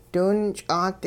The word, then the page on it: diwinch'aat: Hupa